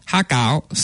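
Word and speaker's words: hakao (蝦餃): So how do you say it in Cantonese? So for the shrimp dumplings, it's called hakao.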